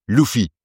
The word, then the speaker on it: Luffy: Wobei dann die Japaner auch versuchen zu erklären, nein, es ist nicht Luffy, weil wir kein R aussprechen können, sondern es ist so eine Mischung aus L und R und das klingt halt wie ein Luffy.